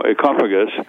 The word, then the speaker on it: Ecophagus: Let me just tell you that the correct pronunciation of my book, as far as I'm concerned, is Homo Ecophagus. – Ecophagus. – Meaning as Ecophagus, as in economy, ecology, etc. Oikos meaning the Greek word for home.